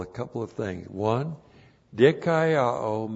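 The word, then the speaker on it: Dikaiao (δικαιόω): D-I-K-A-I-O, long O. Dikaiao.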